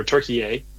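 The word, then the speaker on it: Türkiye: and just learn a ton about Turkey, or Turkey A, as the proper pronunciation.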